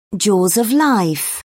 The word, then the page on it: Jaws of Life: British English